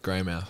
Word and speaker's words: Greymouth: Yeah, Greymouth.